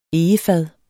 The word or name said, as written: egefad